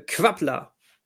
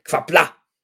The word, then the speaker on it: Qapla': Wahrscheinlich Quappla! Das klang, das klang besser. Ja. Also da steht Q-A-P-L-A und dann so ein, Apost so, so ein Apostroph. Wer weiß, wofür das Apostroph steht. Quappla! – Aber ja, ich würde, ich würde vermuten, Klingonisch wird zu 99 auf der letzten Silbe betont.